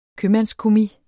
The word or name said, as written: købmandskommis